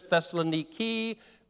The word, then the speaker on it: Thessaloniki: Modern pronunciation is Thessaloniki, biblical Thessalonica, American Thessalonica.